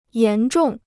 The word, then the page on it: 严重: yán zhòng